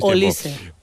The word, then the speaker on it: Olise: Podrías preguntarlo, pero yo ya en la Champions lo hicimos y era Olise.